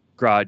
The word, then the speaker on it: Grodd: We can maybe get Gorilla Gorilla God. – Um, Grodd.